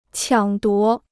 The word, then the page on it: 抢夺: qiǎng duó